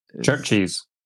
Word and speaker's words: CHVRCHES: It's unpronounceable, C-H-V-R-C-H-E-S. – Churches. – Oh, okay. – The V is an old Scottish way of making a U. – Okay, so Churches is the artist and the mother we share by Churches.